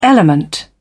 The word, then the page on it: element: British English